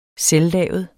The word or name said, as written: selvlavet